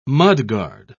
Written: mudguard

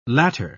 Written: latter

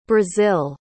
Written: brasil

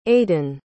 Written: aedan

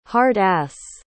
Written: hard-ass

